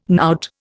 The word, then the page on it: knout: Indian